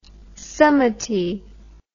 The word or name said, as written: samiti